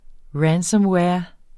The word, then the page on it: ransomware: [ ˈɹansʌmˌwεːɐ̯ ]; [ -ˌwæːɐ̯ ]